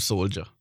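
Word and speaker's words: Solder: Is it Soldier or Soldier? – I just call him Soldier. – Because it's spelled Soldier, but I just hear people pronounce it Soldier.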